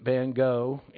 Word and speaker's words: Van Gogh: Those of you know Vincent van Gogh, actually Van Gogh is the proper pronunciation, had a pretty difficult life.